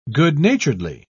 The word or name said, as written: good-naturedly